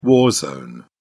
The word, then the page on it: war zone: British English